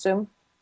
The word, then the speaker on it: Xoom: Is it pronounced Zoom? – Yeah. – Yeah, okay, with X-O-O-M.